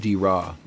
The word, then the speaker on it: Draa: This we think this is the river Dira, which is spelled D-R-A-A, but it's pronounced Dira.